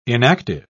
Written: inactive

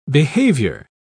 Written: behavior

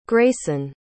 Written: greyson

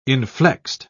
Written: inflexed

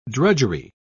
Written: drudgery

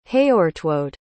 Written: heortwode

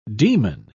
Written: demon

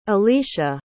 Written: elisha

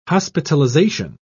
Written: hospitalization

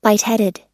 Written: lightheaded

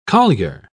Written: collier

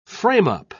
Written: frame-up

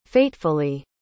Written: fatefully

